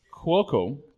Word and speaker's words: Cuoco: Cuoco.